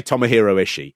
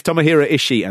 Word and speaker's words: Tomohiro Ishii: I say Tomohiro Ishii. – Yeah. – I don't go, "Tomohiro!" Like that. But that is the slight sort of Japanese way of pronouncing it.